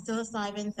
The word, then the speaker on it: psilocybin: All right, so you said psilocybin. Did I pronounce it correctly? – Yeah, psilocybin therapy.